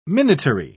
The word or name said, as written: minatory